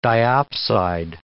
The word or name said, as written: diopside